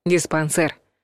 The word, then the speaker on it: диспансер: Так что вариант один — диспансер. Кстати, произносится твердо, через звук «э». Но пишут «е».